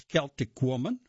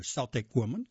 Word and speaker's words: Celtic Woman: Wednesday, March 16th, it's Celtic Woman, or Celtic Woman, can be pronounced either way, Postcards from Ireland.